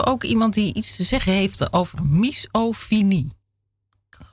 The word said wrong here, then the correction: misofonie: hoop dat ik het goed uitspreek. Nee, misofonie spreek je het uit.